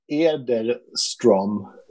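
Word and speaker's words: Erdeström: Erdestrom. You know, the Swedish O with the two dots over, it's Ö.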